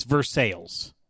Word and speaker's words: Versailles: And I'm like, yeah, the town in Indiana called Versailles is not pronounced the way you think here. It's Versailles here in America. It's Versailles, Indiana. It's not Versailles.